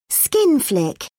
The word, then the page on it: skin flick: British English